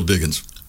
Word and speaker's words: Diggings: So no matter how it's spelt, it's still Diggins.